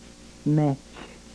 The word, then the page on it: mech: Yurok